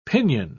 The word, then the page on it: pinon: British English